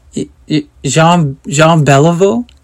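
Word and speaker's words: Jean Béliveau: And I said, Jean Beliveau is coming here? She says, oh, is that how you say his name? Yes.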